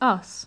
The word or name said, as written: us